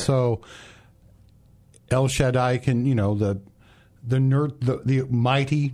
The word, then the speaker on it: El Shaddai: How would you pronounce El Shaddai? – El Shaddai. – El Shaddai? So you would put the emphasis on the first syllable?